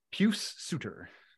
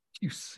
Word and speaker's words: Pius Suter: Pius Suter. – Pius. Okay. – Pius.